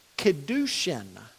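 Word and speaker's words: Kiddushin: That the kedushin was the first stage, the betrothal. The way we would spell it in English would be K-I-D-D-U-S-H-I-N. U-S-H-I-N, Kedushin. And then the Huppah, H-U-P-P-A-H.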